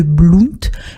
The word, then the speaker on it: Blount: Blount.